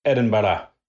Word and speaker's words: Edinburgh: And then actually in May, our first international Christ-Centered and Clear conference in Edinburgh, Scotland.